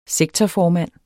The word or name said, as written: sektorformand